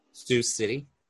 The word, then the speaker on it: Sioux City: how do you say it? Oh, you're muted. – City. – What is it? – Sioux City. – Sioux City. Okay, see, I knew I would mess it up.